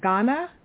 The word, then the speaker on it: Gaana: Is J-I-O-S-A-A-V-N. Is it Jiosavan? – No, it's Jiosavan. – Jiosavan, okay. – Yeah. – And the other one is G-A-A-N-A. Is that Ghana? – Yes, that's Ghana.